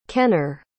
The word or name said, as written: kenner